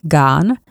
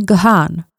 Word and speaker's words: Gahan: Mimochodem, výslovnost jména frontmana Dave Gahan je Gahan a ne Gahan.